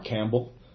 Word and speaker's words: Campbell: Lamar Campbell, last name C-A-M-P-B-E-L-L.